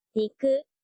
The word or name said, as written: niku